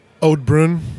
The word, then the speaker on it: Oud Bruin: How do you say that in English? – Oud Bruin.